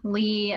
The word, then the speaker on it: Leigh: So it's my first name, Jillian Lee, L-E-I-G-H, it's spelled funny, _ nutrition.